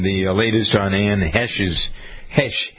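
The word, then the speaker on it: Heche: Ann Hesh, I believe that's right, isn't it? – Yeah, Hesh.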